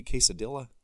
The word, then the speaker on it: quesadilla: You want that soapy quesadilla? – It'll clean you right up. – It's one of my pet peeves. Everybody should know you don't pronounce the L's in quesadilla.